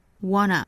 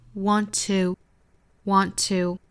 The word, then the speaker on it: wanna: 首先我们注意 want 和 to 这两个词呢，在美音里，尤其在口语里面，一般会读成 wanna。那英音的话我们读 want to, 或者是弱读的 wanta。